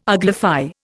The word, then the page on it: uglify: Indian